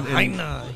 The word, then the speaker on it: Lahaina: Lahaina. He said it. – Lahaina. – It's not East LA, it's Hawaii. Say it right.